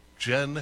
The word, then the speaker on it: Jyn: J-Y-N, pronounced Jen as in the drink.